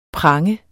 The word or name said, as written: pranger